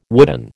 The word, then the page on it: wooden: American English